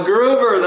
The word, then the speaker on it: Groover: So first of all, you've got that cool name, G-R-O-O-V-E-R. How do you really pronounce it, Marty? – Just like it sounds, Grover. – Yep. Grover.